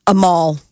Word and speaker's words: Amal: Amal.